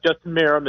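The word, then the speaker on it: Justin Meram: You were right the second time. Justin Marom is his name.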